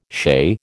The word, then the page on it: chez: American English